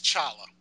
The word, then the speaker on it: T'Challa: It's like Chichula? – T'Challa Walla? T'Challa? – It's T'Challa.